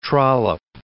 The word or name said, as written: Trollope